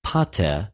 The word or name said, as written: ποτε